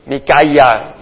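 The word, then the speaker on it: Micaia (מיכה): ¿Y cómo se dice en hebreo? Micaia Y quiere decir, ¿quién como?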